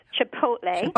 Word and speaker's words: chipotle: One that's interesting to me is chipotle. I thought everyone pronounced that correctly, but apparently a lot of people say chipotle. So it's chipotle.